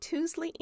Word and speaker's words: Tuesley: The book is Jane Austen's Table by Robert Tousley Anderson. Tousley is spelled Tuesley. It's a weird spelling.